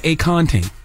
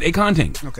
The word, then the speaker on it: Akonting: and think about acorn drinking a ting. Mm-hmm. And that's how you pronounce it, Akonting.